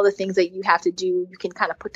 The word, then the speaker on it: Teuxdeux: It's called To-Do, but it's spelled T-E-U-X-D-E-U-X. So it's like fancy To-Do. I know, I was about to say, like, you're fancy. – Yeah, so it's just a spelling that's fancy.